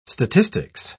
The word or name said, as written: statistics